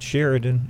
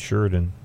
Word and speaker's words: Sheridan: So, and people like to, it's not Sheridan, it's Sheridan. I mean, I know what.